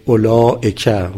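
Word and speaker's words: أولئك: مثلا آمنو، خب الف آخرش خونده نمیشه، یا فرض کنید اولائک، واو بین همزه و لام خونده نمیشه.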